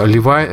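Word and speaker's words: Levine: Э, Левай, э, Левайн, Левин, наверное, правильно говорить.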